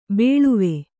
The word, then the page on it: ಬೇಳುವೆ: kannada; bēḷuve